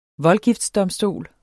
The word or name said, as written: voldgiftsdomstol